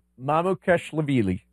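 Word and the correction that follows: Mamukelashvili: You're close. Mamu Keloshvili. – Keloshvili. Mamu Keloshvili.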